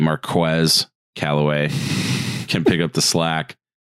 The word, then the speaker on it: Marquez Callaway: Uh, I need to evidently pronounce his name correctly. It's not Marquez Callaway. It is Marquez Callaway.